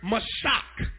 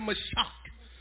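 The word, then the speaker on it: mashach: Now that word anoint here in the Hebrew is the word mashak. Everybody said mashak. I spell it for you. M-A-S-H-A-C-H. M-A-S-H-A-C-H.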